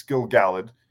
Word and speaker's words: Gil-galad: So now we've seen Gil-galad or Gil-galad. I always say it wrong. It's Gil-galad and Celebrimbor be really stupid and really without honor and contrived.